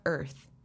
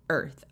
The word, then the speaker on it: Irth: I'm your host, Dr. Brittany Barreto, and today we interview Kimberly Seals Ehlers, the founder of Irth. That's spelled birth without the B. Irth, I-R-T-H.